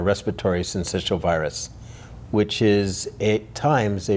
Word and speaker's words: syncytial: Respiratory syncytial virus.